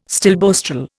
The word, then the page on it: stilboestrol: Indian